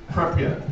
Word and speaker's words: Pripyat: A lethal cloud of radioactive material gathered over the nearby town of Pripyat. I'm not saying that right, probably, but Pripyat. – Thank you.